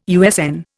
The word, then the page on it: USN: Indian